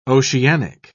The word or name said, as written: oceanic